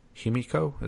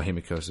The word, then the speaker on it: Himiko: I feel like she has more of a heart than Himiko. Is that how you pronounce it? – The musician. Yeah.